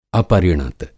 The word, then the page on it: अपरिणत: Hindi